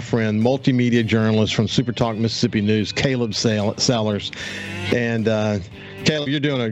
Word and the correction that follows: Salers: I'll get better at that, Sailors.